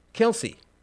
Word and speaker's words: Kelce: Okay, how the hell do you pronounce that? All right, I will dip into the satanic swamp of Wikipedia for just one moment. Kelce. Kelce? Like the restaurant?